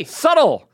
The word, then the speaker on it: subtle: Subtle. – Subtle. – Toll. I'm saying toll. – The B Manhattan. – The B is subtle. – The B is very subtle. It's a silent B.